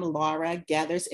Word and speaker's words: Laura Gethers: As she stated, I am Laura Gethers, and thank you for saying my name correctly.